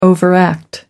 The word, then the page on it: overact: American English